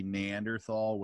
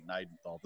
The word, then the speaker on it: Neanderthal: And it's usually Neanderthal, which there's not even an R in there. And so, but I get it. Like in German, the proper pronunciation would be Neidenthal.